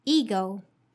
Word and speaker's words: ego: Okay, now the fourth pronunciation tips ego. Ego. Let's notice the pronunciation of the first letter E. Here, the pronunciation is not E, but it's a long I sound, which is E. E. So it's ego.